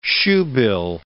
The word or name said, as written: shoebill